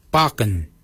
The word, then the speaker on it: baginy: For beard, we say barken. Barken is spelled B-A-G-I-N-Y.